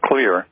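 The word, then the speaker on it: Xlear: Put in there that the reason it's pronounced Clear is because X in Finnish, which is where they did most of the leading research on xylitol, is pronounced like a soft K.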